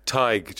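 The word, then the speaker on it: Tadhg: Tig. Just take the er off the end of Tiger.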